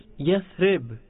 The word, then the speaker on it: Yathrib: Correct pronunciation is Yathrib. Calling it Yathrib is impermissible. It's a sin. Because Yathrib means a place of calamities, afflictions.